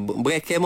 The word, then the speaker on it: bereke: Tapi perkataan kita malam ni ejaannya adalah B-E-R-E-K-E. Bunyi dia adalah bereke.